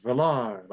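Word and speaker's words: Vallar: I went with the French velar. – Like, yeah. yeah. – yeah, yeah, That's how most people, I think, pronounce it when they first see it.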